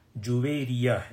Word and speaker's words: جویریہ: تو آج کی مجلس میں حضرت امہات المؤمنین میں حضرت جویریہ، نام جو ہے صحیح طریقے سے لینا ہے، کیونکہ بعض مرتبہ سننے میں آتا ہے کہ بچیوں کے نام رکھتے ہیں لیکن اس نام کا جو ہے پرونانسیشن صحیح نہیں ہوتا. جویریہ ہے یہ اصل میں، یہ ایک مبارک خاتون تھی، جویریہ بنت حارث رضی اللہ تعالی عنہ.